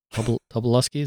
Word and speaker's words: Tobolowsky: Tobolowsky, is that how you pronounce it? – Yeah.